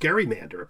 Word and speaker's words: gerrymander: but when you start with a G-E-R, it usually is a soft G. – I think so, because originally it was gerrymander. I mean, it originally was pronounced gerrymander, apparently, and then it softened.